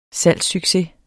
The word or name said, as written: salgssucces